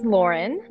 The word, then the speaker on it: Loryn: Well, my name is Loryn.